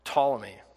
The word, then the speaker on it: Ptolemy: The one that matters to us is Ptolemy. I think I'm saying that right. P-T-O-L-E-M-Y. And then the other three were Lysimachus, Cassander, and Selinus.